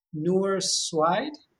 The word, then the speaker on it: Noor Sweid: And I'm hopefully going to pronounce her name correctly, Noor Sweid.